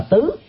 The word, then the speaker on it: tứ: Thực ra đó, trong ngữ cảnh đó phải đọc là tứ, nhưng mà người Việt Nam mình, nhất là dân miền Nam, thường đọc trại âm.